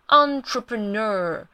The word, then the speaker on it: entrepreneur: OK，首先我们要看到的呢，就是这个大字 entrepreneur， entrepreneur， on， en 把它念成 un 那个 on，然后 trepreneur。不要念 P-R-E pre，念成 per entrepreneur ner entrepreneur。